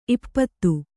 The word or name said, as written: ಈಪ್ಪತ್ತು